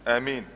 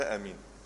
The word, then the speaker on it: آمين: إذا قال آمين، طب آمين تتنطق إزاي أخو أسامة؟ أه؟ بالآه؟ يعني قولها في بعض الناس يقول لك امين، صح كده؟ صح؟ امين. في ناس هم بيصلوا بيقول لك امين، هي نطقها ازاي؟ ها؟ – بمد يعني هي بالمد ولا بالقصر؟ بالمد. الصح في كلمة أمن أنها بالإيه؟ بالمد.